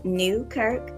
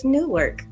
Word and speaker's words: Newkirk: Last name is Newkirk. It's only 2 syllables. But it's so weird how people will see my name and will still miss the K. Who is Newark? I don't know who that is.